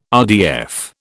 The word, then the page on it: RDF: British English